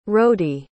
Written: rodi

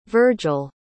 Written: virgel

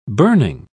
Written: burning

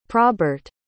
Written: probert

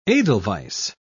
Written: edelweiss

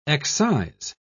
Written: excise

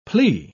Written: plea

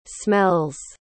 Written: smells

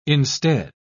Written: instead

